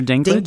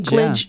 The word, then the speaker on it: Dinklage: How do you say his last name? Peter Dinklage. Dinklage. Yeah. Dinklage, yeah.